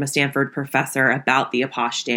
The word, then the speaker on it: Apache: So before we go any further, I just want to say that the dance is actually pronounced Apache. The characters in the episode we watched this week pronounce it Apache, but for the rest of the episode, I'm going to say Apache because that's the correct original French pronunciation of the dance, just so we're all on the same page.